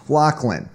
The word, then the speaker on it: Lachlan: I mean, it started with Lachlan Edwards, which it's pronounced Lachlan,